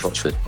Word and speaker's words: Rochford: Yeah, Rochford.